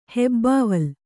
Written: ಹೆಬ್ಬಾವಲ್

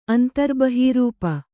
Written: ಅಂತರ್ಬಹೀರೂಪ

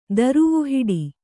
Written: ದರುವುಹಿಡಿ

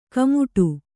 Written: ಕುಮುಟು

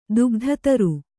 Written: ದುಗ್ಧತರು

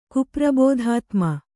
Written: ಕುಪ್ರಬೋಧಾತ್ಮ

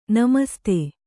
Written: ನಮಸ್ತೇ

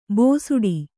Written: ಭೋಸುಡಿ

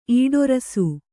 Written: ಈಡೇರಿಸು

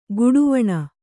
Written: ಘಡಾವಣೆ

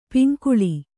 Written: ಪಂಗೋಳಿ

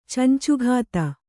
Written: ಚಂಚುಘಾತ

